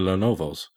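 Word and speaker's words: Lenovo: Most of the people are going for the Lenovos. Tell me I actually said that right. – Yeah, you did.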